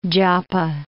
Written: Joppa

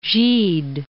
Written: Gide

